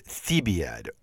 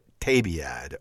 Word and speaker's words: Thebaid: The reference here is to Statius's Thebaid, or Tabiad, or Thebaid, depending on how you want to pronounce the name of Statius's great epic.